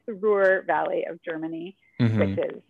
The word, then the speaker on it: Ruhr: Is it Ruhr? Ruhr? – It is. Yeah, it's the Ruhr.